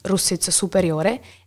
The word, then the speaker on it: Russiz Superiore: So Russiz Disore, then in Italian it's Russiz Superiore, and Russiz Inferiore, where there's another winery, not run from our family, that it's called Fondazione Villa Russiz.